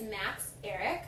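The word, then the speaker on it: Max Ehrich: And her fiancé is Max Eric. I looked it up. That is how you pronounce it, even though it is spelled with a lot of H's involved.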